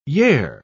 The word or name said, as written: yare